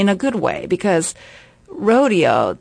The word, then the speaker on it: Rodeo: Now, this ballet was not written for Martha, but I asked this artistic director, how do you pronounce, is it Rodeo or Rodeo? Because I've heard it both ways, mm-hmm. and she said Rodeo.